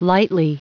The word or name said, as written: lightly